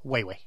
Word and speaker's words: Huawei: Huawei. Huawei. Mm. Huawei. Dette er faktisk eh, Det uttales Weiwei.